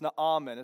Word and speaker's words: Naaman: It's Naaman. It's three syllables.